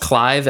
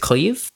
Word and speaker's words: Cleve: His name is, I've heard it pronounced Clive, and I've heard it pronounced Cleve Baxter. – I hope it's Cleve. – The reason why I think it's Cleve is that he was named for Grover Cleveland Baxter.